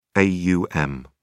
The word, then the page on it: aum: British English